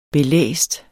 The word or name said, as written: belæst